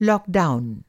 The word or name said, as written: लॉकडाउन